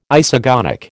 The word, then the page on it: isogonic: American English